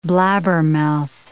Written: blabbermouth

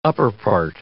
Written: upperpart

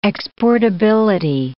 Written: exportability

